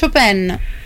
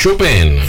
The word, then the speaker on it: Chopin: Ci siamo sbagliati tutti la pronuncia. – Com'è la pronuncia? – Perché è Chopin. – Ah, Chopin.